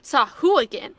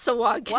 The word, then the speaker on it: Sahuagin: I just want to point out that, like, sometimes the pronunciations aren't reliable because, you know, D&D Beyond, in my opinion, pronounces Sawagin wrong. They have Matt Mercer confidently saying, Sahuagin. And I'm like, no, it's Sawagin.